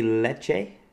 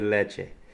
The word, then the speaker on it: Lecce: We went to the boot heel of Italy, Lecce. I always want to say it like Spanish for milk. That's yeah. Some good leche. L-E-C-C-E. – Yep. – Is how it's spelled.